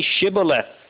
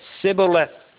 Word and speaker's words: Shibboleth: Then say Shibboleth, was the request of Jephthah's soldiers. The Ephraimites could not say this. They said Sibboleth. They could not frame the word and pronounce the H.